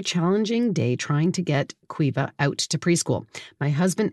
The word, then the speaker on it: Caoimhe: It says that the daughter, Cuiva, it's a Gaelic name, and I never would have guessed it from the way it's spelled, C-A-O-I-M-H-E, but Cuiva. So Cuiva seems to have some serious sensory issues.